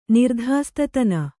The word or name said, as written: ನಿರ್ಧಾಸ್ತತನ